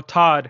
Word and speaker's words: Todd: Todd Father, like Todd McFarlane. You know, Todd, it rhymes with God.